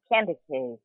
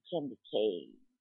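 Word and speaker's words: Kandake: And that was a very, very interesting clip on the candy cave. I've actually been pronouncing that incorrectly. I've been saying the Candace, but it's candy cave, King B, just like K and King B, candy cave.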